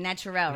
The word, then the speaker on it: Naturel: Like, but that's how you pronounce his name, Naturel, right? – Yes.